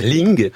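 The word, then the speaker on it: Ling (令): En chinois, l'idéogramme Rei se prononce Ling,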